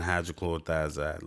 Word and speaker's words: hydrochlorothiazide: I started out with Losartan, and then with the Losartan, I was put on hydrochlorothiazide because I actually said that wrong earlier. I was put on Losartan and hydrochlorothiazide.